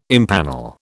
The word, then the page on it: empanel: British English